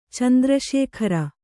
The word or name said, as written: ಚಂದ್ರಶೇಖರ